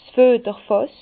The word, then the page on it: 's Füeterfàss: Bas-Rhin; Herrlisheim